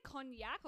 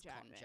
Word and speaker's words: konjac: Would you say cognac or konjac? You know how it's like... Because I think it's Japanese. – Cognac. That's cognac isn't spelled like that. So it'll be... I reckon konjac, it's konjac.